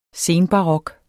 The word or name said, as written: senbarok